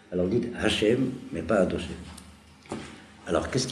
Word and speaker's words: Hachem: Alors on dit Adoshem, ça fait trois syllabes. Mais c'est un barbarisme épouvantable. Enlevez-vous ça de la tête.